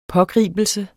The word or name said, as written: pågribelse